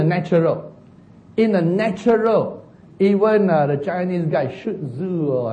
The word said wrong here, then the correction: Sun Tzu: I cannot pronounce his name correctly. Okay, Sun Tzu, thank you.